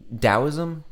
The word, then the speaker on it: Taoism: You can definitely reach back and find hints of this in Hinduism and Taoism and I mean Taoism. Did I say that wrong? I think I said Taoism with a hard T, but you know what I mean.